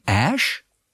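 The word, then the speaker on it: Æ: Ähm, dann dieses AE wird ausgesprochen Ash.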